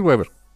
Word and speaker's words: Weaver: Y se lo dijo a un reportero así de oye, tú siempre he tenido problemas con tu apellido, ¿cómo se pronuncia? Es Weaver. No los corrijo, pero porque sé que se friquean, pero es Weaver.